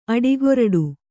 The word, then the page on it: ಅಡಿಗೊರಡು: aḍigoraḍu